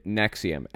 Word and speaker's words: NXIVM: In 1998, Keith Rainier and Nancy Salzman founded Nexium, NXIVM is how it's spelled, a personal development company offering executive success programs, ESPs, and a range of techniques aimed at self-improvement.